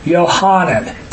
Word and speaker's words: Yohanan: We're going to go to Luke 1, Yohanan. – What? – It's always interesting with us. For some reason, we shorten the name into one John, one syllable. But his name is actually Yohanan, three syllables.